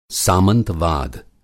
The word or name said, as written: सामंतवाद